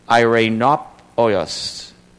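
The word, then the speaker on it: eirēnopoios: The Greek is spelled E-I-R-E-N-O-P-O-I-O-S. Irenopoios.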